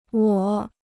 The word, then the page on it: 我: wǒ